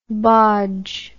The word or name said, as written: bodge